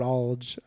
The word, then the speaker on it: Alge: And especially after reading this interview where he said, this guy, Tom Lord Alge, how do you say his name? – I'd say Alge.